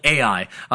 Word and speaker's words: Ai: And so for the Israelites, they just immediately move on from Jericho and they go on to this next city called Ai. That's how you say it. That's how you spell it. It's literally the letter A, then the letter I, Ai.